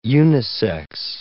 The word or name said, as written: unisex